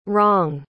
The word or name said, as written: rong